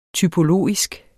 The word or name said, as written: typologisk